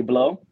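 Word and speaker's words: Blot: My name is Donnie Blow, FBLOT. The T is silent because it's French.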